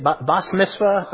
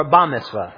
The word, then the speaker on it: bat mitzvah: A woman, how do you say it? Bas mitzvah or ba mitzvah? Ba, ba, ba.